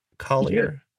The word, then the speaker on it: Collier: Collier.